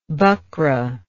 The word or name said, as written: buckra